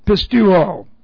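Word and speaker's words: pisteuo: Present active imperative of our verb pistuo. P-I-S-T-E-U long O.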